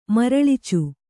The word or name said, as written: ಮರಳಿಚು